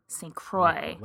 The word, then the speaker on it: St. Croix: Yeah, and the LaCroix comes from the St. Croix River, which flows between Wisconsin and Minnesota, and that's how you pronounce the river name. St. Croix. – Like Fond du Lac.